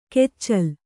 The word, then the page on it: ಕೆಚ್ಚಲ್: keccal